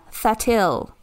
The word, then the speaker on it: Thattil: Maria Thatil. T-H-A... – Can you spell it? Yeah. – T-H-A-T-T-I-L. Maria Thatil.